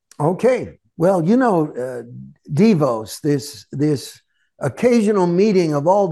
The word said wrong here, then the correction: Davos: They want to give us or what, I don't know, I mispronounced their name. – Davos, yep.